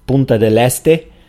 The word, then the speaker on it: Punta del Este: So I'm going to be speaking about Punta del Este, or in a better Spanish pronunciation, Punta del Este. And also Montevideo, the capital of Uruguay, and finally Colonia del Sacramento, that's a historic city in the south of Uruguay.